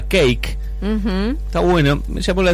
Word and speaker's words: Cake: Se llama Cake, la banda. Suena, se escribe Cake con C-A y K-I-E. – Como un pancake. – Como un cake.